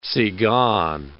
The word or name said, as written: tzigane